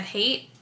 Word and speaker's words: Hayte: And I'm Mad Hate on Instagram, and Hate is spelled H-A-Y-T-E.